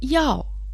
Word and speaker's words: 药: Слово «яо» произносится также в четвертом тоне, переводится как «лекарство».